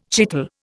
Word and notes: chital: Indian

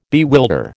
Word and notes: bewilder: American English

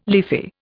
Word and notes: leafy: Indian

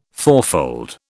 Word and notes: fourfold: British English

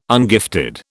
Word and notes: ungifted: British English

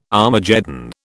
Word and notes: Armageddon: British English

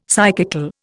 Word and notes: psychical: Indian

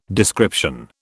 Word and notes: description: British English

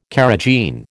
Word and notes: carrageen: American English

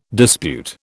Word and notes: dispute: British English

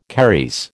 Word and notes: caries: American English

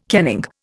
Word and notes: kenning: Indian

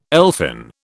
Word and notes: elfin: British English

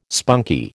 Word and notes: spunky: American English

